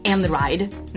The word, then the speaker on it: Einride: I always thought it was Einride. I thought it was E-I-N, and I thought it was German, but it's actually pronounced Einride.